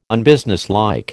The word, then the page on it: unbusinesslike: American English